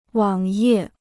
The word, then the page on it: 网页: wǎng yè